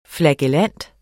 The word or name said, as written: flagellant